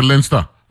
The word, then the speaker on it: Leinster: Leinster. You pronounce it Leinster? Okay. – Yeah, Leinster.